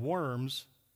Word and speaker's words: Worms: And they met at a particular place spelled in Germany W O R M S. Now, pronounce that for me. You would think it's worms, but it's in Germany, so it's Worms, okay?